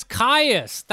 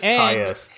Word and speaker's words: Kyuss: How do you spell that guy's name? – K-Y-U-S-S. – That's Caius. That's the freaking. He's a huge deity in Dungeons and Dragons. He's the Lord of Worms. – It is Caius.